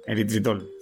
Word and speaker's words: eritritol: Eritritol. ¿Lo he dicho bien? Eritritol, sí.